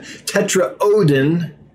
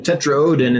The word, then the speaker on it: Tetraodon: Tetraodon and um, you let me know their Pao. So I I was able to I hope list them correctly um, when I listed them. Seriously, Fish called them Tetraodon, and that's usually what I go with because usually they're right.